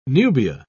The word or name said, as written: nubia